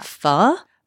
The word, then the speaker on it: pho: Always asking a question, add a question mark at the end of the word pho.